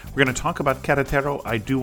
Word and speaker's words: Querétaro: I'll go with like Querétaro, which is close enough.